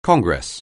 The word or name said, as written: Congress